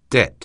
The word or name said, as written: debt